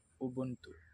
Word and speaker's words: Ubuntu: Ubuntu, pronounced Ubuntu, is a philosophy that reminds us of our interconnectedness and shared humanity.